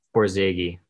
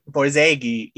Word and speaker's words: Borzage: We'll get deeper into it, but the director, oh, please say his name again. Borzage. Borzage, you know, he's mostly from the silent film era.